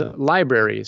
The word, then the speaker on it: LBRY: One of those platforms is Library. So it's spelled LBRY.